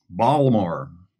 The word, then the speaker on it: Baltimore: Baltimore. – Yeah. – You pronounce the T. I thought you weren't supposed to do that if you're local.